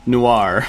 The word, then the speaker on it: Noir: I was about to buy the Piappali Noor. Is that how you say it? Noor? It looks like... – Sounds like you're speaking a different language. Piappali Noir.